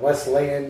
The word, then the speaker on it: Wesleyan: Max, born and raised in Newton, Massachusetts, before heading off to, how do you say that, Wesleyan University? – Yep.